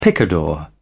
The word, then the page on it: picador: British English